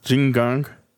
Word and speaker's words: Qigong: And oh, like so that's how you pronounce it. Qigong.